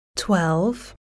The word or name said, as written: twelve